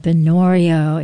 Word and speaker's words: Binnorie: Check out the new album called Binorio. It's spelled B-I-N-N-O-R-I-E from Louise Bicken. Last name is B-I-C-H-A-N.